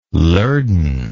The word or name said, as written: lurdan